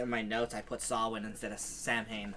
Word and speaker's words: Samhain: So it's actually Samhain, which I thought was really weird. – Samhain. – That's how it's pronounced? – That's how it's pronounced, yeah.